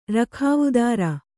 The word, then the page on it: ರಖಾವುದಾರ: Kannada